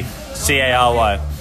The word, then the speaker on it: Cary: Carey. Oh, actually, it's Hugo _ S. Carey. – How do you spell Hugo? Just spell it all out for us. – So it's Hugo _ S. Carey, Carry.